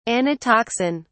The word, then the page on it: anatoxin: British English